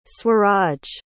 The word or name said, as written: swaraj